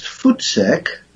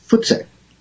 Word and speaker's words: voetsek: And it's spelled V-O-E-T-S-E-K, but it's pronounced like your feet, like footsack? – Yes, because the V in Afrikaans is pronounced as an F. – Oh, okay. – So, footsack.